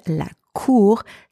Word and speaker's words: cour: The first word, la cour, spelled C-O-U-R, mean either courtyard or playground or even court, as in tribunal. The letter C in that word is pronounced K, and